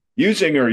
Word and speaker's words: Usinger: I always say this wrong, Usinger sausage. – Usinger, you got it.